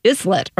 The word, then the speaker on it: islet: And the term is islet. But it's spelled I-S-L-E-T, as if it's islet or something, but it's not. It's islet.